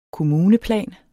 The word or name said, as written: kommuneplan